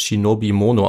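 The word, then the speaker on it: Shinobi Mono (忍者): Denn das Wort, auf das Ninja zurückgeht, kommt aus dem 16. Jahrhundert und wird eigentlich als Shinobi Mono ausgesprochen, wie okay. wir es in der Quelle am Anfang gehört haben. Ja. Und den ersten Teil des Wortes Shinobi spricht man heute als Nin aus, und den zweiten Teil Mono als Ja.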